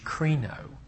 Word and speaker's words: krino: The term that he uses in Matthew 7, 1 and 2 is pronounced in the Greek language, krino.